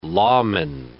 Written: lawman

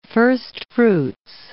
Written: firstfruits

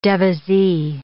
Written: devisee

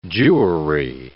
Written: Jewry

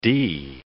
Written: dee

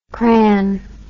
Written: cran

